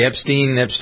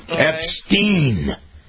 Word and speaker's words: Epstein: You have an update on Jeffrey Epstein. – Now you've got me doing it. We were talking Epstein. E-E-N. Well, as if it was spelled like that.